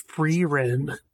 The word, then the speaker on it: Frieren: Really enjoying Frieren. That is how you pronounce it. – It's Frieren.